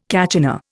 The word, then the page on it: kachina: Indian